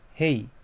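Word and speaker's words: 丙: 音読みで読みますと、こう、おつ、へい、てい、ぼ、き、こう、しん、じん、き、と読みます。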